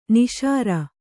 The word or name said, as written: ನಾಸೀರ